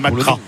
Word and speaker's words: McGrath: Alors, j'ai demandé si on disait McGrath ou McGrath, et c'est McGrath. – D'accord. – McGrath. Lui, il prononce rien à la fin.